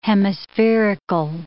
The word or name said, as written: hemispherical